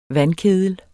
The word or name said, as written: vandkedel